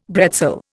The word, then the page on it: bretzel: Indian